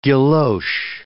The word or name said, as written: guilloche